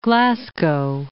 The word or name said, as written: Glasgow